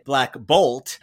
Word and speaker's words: Black Bolt: I also want to comment, Nathan, I am now obsessed with, and I'm going to only say his name the way you say it, Black Bolt, because I really like putting the emphasis on Bolt. not black, because he's mostly wearing like a blue these days.